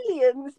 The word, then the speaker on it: chameleon: And then you'd have the Charmeleon. Charmeleon? I'm saying that right. – Chameleons. You mean the chameleons? – Chameleon.